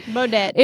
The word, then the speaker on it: Baudet: I think this T is silent. – Baudet.